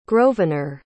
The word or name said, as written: grosvenor